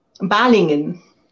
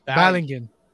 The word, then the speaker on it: Balingen: I can't speak English, and now I'm going to try and pronounce a country in Germany. Balingen? – Balingen. – Balingen. – Yeah. Perfect.